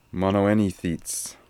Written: Monoenithits

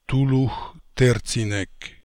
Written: Toolookhterzinek

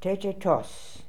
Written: Tetetos

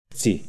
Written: Dzi

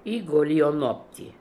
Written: Egolionopty

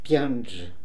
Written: Pianje

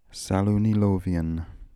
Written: Salounilovian

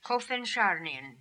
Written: Kofensharnian

